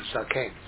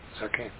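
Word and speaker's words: Zaken (זקן): Zaken. Zaken.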